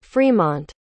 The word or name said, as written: fremont